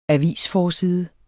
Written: avisforside

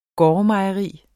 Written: gårdmejeri

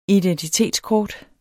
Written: identitetskort

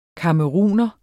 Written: camerouner

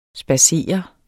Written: SPADSERER

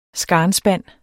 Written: skarnspand

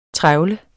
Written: trevle

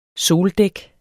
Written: soldæk

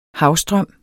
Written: havstrøm

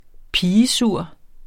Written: pigesur

